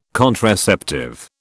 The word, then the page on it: contraceptive: British English